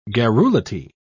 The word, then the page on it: garrulity: British English